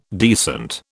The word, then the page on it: decent: British English